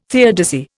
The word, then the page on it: theodicy: Indian